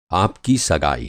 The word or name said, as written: सगाई